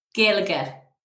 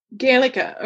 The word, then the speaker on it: Gaeilge: Okay, so when you study, how do you say Irish again? – Gaeilge. – Gaeilge. Okay. – G A E I L G E.